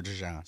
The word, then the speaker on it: DeJean: The Eagles, according to Burt Breer, traded up to 40 and took Iowa defensive back Cooper DeJean. Is that how you say it? – That's it. – Yeah. – Cooper DeJean.